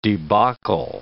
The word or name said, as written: debacle